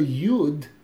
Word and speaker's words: Yud: Then Rabbeinu Zal spoke about, he defined the concept of Keseder as the letter Yud, the way we pronounce it, Yud, Vav, Dalet.